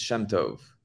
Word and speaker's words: Shemtov: My last name is Shemtov.